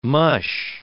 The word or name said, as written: mush